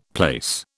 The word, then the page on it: place: British English